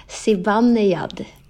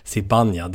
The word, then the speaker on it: Zibanejad: Och igen då, hur ska det uttalas? – Zibanejad. – Zibanejad, det är det. Zibanejad.